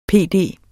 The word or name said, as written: PD